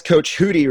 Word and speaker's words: Hootie: Welcome to the podcast, Coach Hootie, right? – Yes, Coach Hootie, yeah. – Does that get mispronounced all the time? – Sometimes. I always say, remind people it's like Judy, you know.